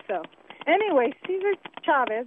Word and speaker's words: Chavez: And by the way, it is pronounced Chavez.